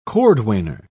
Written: cordwainer

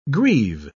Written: greave

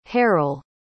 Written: haral

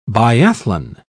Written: biathlon

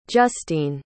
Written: justene

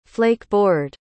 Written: flakeboard

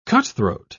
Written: cutthroat

